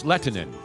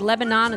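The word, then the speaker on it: Lehtinen: No, it's Lettinen. – I know, but is it like Lebanon? It's Lebanon? – Like no, Bologna? It's Lettinen. Okay. That's the way it's pronounced.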